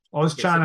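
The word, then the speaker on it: Özcan: Ozchan, I think is how it's said, yeah.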